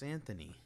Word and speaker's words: Anthony: No, it's Anthony.